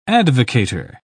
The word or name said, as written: advocator